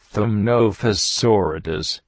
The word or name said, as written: Taxes